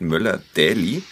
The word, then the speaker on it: Möller Daly: Ähm, also neueste Variante, das R in Möller, schreibt sie, wird ausgesprochen, aber wenn man schnell spricht, kann es verschluckt werden, wie beim deutschen Namen Müller. Und Daly spricht man Daly aus.